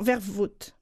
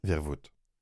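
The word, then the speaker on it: Vervoort: Elle s'appelle, je ne sais pas si ma prononciation est bonne, mais Marieke Vermouth. – Vermouth. – Non, Vervoute. – Vervoute.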